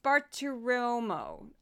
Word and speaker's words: Bartiromo: It's Bartiromo.